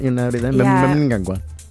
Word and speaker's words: Mnangagwa: So imagine you're from Liverpool... All and right. You're talking about your grandma, and her name is Gagwa. Mnangagwa. So that's how I do it.